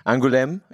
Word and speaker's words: Angoulême: Angoulême, in the southwestern part of France.